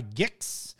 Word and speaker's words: Gix: Mishra claimed by Gix. Gix? Is that how you say it? Gix? Yeah. Yeah, all right.